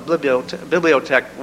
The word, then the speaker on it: bibliotect: I'm sure you all read the announcement for today's uh, talk and wondered what a bibliotect was. It's not to be confused with biblioteca, meaning library, but uh, is pronounced and spelled and means um, very much what architect means.